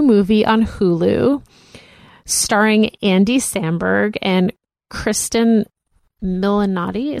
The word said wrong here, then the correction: Kristen Milioti: Is that who you say her name? – I think it's Christine Milotti. – Milioti. – Kristen Milioti.